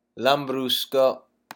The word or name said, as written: lambrusco